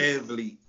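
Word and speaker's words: VanVleet: How you say his name? Fred Van Fleet? – Van Fleet.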